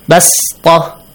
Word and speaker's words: بسطة: Basta. – Yes. – Pronounce it like a seen.